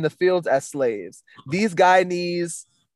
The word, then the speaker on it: Guyanese: Being Guyanese, we have we have to explain to people a lot of times that it's not pronounced Ghana or Guinea.